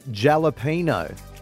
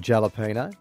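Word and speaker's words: jalapeño: Pronouncing the J in jalapeno makes you a subtle bogan.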